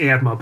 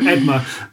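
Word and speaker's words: Eadmer: And is that where the historian Edmar, I was pronouncing Eadmar, but I'm obviously wrong, yeah. You know better than me. So Edmar, tell us a bit about him.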